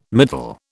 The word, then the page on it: middle: British English